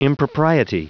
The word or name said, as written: impropriety